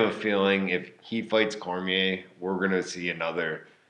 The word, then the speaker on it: Cormier: Like, good fighters like Cormier, or is that how you pronounce it? – Yeah, Cormier.